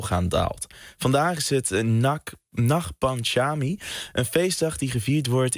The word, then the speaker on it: Nag Panchami: Je spreekt het uit als Nag Panchami.